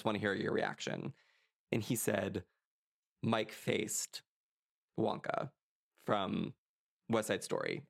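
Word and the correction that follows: Faist: And I think it's, is it Faced? – Feist. – I don't know how you pronounce it. Mike Feist.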